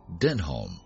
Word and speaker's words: Denholm: Another considered that the trail named Denim hadn't come from the jeans he wore to hike, but rather that he had named himself Denholm, which is often pronounced similarly and alludes to a geek reference on a British series with a cult following, IT Crowd.